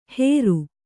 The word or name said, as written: ಹೇರು